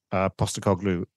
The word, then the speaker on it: Postecoglou: Yeah, and Des, I am really excited, actually, seriously, for Tottenham Hotspur, because I'm a big Ange Postecoglou fan now. And you pronounced his name right.